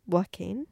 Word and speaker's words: Joaquin: Joaquin? – Yeah.